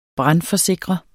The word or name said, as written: BRANDFORSIKRET